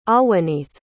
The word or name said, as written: ahwanith